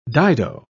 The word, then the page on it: dido: British English